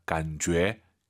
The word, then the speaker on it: 感觉: 이 제이유이는요, 주애가 아니에요. 너의 죄입니다. 感觉, 感觉. 이렇게 발음하시기 바랍니다.